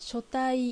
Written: Shotai

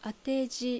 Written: Ateji

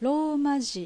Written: Romaji

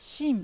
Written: Shin

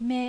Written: Me

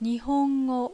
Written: Nihongo

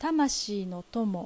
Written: Tamashii

